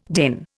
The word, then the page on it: DIN: Indian